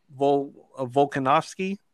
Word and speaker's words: Volkanovski: The co-main is Alexander Volkanovski.